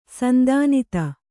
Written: ಸಂಧಾನಿತ

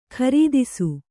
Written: ಕೀರ್ತಿಸು

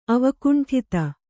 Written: ಅವಕುಂಠಿತ

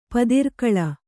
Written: ಪದಿರ್ಕೊಳ

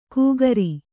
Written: ಕುಕ್ರಿ

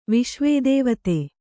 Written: ವಿಶ್ವದೇವತೆ

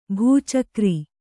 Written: ಭಚಕ್ರ

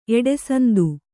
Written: ಎಡೆಸಂದು

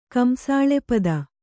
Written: ಕಂಸಾಳೆಪದ

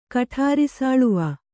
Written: ಕಠಾರಿಸಾಳುವ

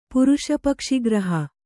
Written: ಪುರುಷಪಕ್ಷಿಗ್ರಹ

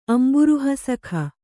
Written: ಅಂಬುರುಹಸಖ

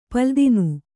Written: ಪಲ್ಲುದಿನು

